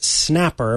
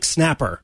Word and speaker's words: Xnapper: So the app is pronounced Snapper, but it's spelled X-N-A-P-P-E-R. – Snapper. – But they specify, because I used to pronounce it Snapper, and then they added this little subline to their the website that says read like Snapper.